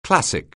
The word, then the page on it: classic: anglais